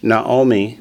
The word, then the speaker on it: Naomi: Naomi. – Naomi. – Mate. – Mate. – Remember in Naomi, that second vowel is called a hatav kamates, and it's pronounced like an O.